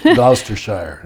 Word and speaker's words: Gloucestershire: Yeah, I'm going to butcher this, Gloucestershire, is that right? – Gloucestershire.